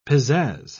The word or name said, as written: pizzazz